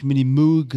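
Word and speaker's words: Minimoog: Der Mini-Moog, also das ist ein das wird amerikanisch ausgesprochen, nicht Mini-Moog, sondern Mini-Moog war die Revolution,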